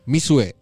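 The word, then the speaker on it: Mixue: Yang ada di Mixu, oke? Bacanya Mishue. Itu artinya bola salju.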